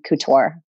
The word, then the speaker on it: Couture: It's Wentworth and then Couture.